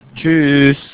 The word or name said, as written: tschüss